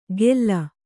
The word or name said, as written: ಗೆಲ್ಲ